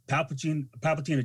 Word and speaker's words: Palpatine: And what every Sith wanted to do, Palpatine achieved, but it started.